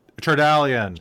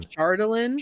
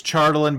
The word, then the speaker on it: Chardalyn: Because I was like, it's I, it, Chardalian. – Chardalyn. – Chardalian. Yeah. Yeah. It's it's. I know it's Chardalyn, but it's Chardalian because that sounds better.